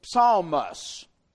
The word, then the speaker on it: psalmos: In the Greek, it is spelled with what we would spell it, a transliteration of P-S-A-L-M. And in the Greek, both the P and the S are pronounced. It is psalmos, and derives the English noun psalm, or its plurality, psalms.